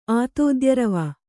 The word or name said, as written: ಆತೋದ್ಯರವ